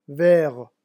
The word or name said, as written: vert